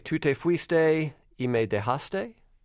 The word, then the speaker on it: Tu te fuiste y me dejaste: Tu te fuiste y me dejaste? Did I say that right? – That's right.